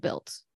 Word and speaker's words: Bild: Well, because it's spelled B-I-L-D. – Okay. Not like she's built.